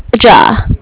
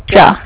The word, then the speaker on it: Jia: It's J-I-A. – J-I-A? – Mm-hmm. – So is it, do you pronounce it Gia? – Ja. Ja.